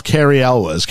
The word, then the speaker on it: Elwes: There is a sizable part for As You Wish himself, Cary Elwes, I think is actually how you pronounce it.